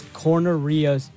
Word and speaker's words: Corneria: How do you pronounce it? Corneria? This is Corneria. – Corneria. Yeah, yeah, yeah, yeah.